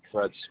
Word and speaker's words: Fred: Fred Spaggett.